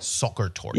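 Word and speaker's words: Sachertorte: Is that how it's pronounced? But yeah. – It's like soccer tort.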